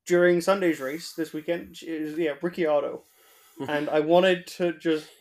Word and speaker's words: Ricciardo: But she was pronouncing Daniel Ricciardo as Ricciardo about a dozen times in the race. – Ricciardo. – Ricciardo, I think is how she pronounced it.